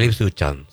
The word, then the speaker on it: Ipswich Town: El, a ver si lo puedo pronunciar bien, Ipswich Town. – El Ipswich Town, sí.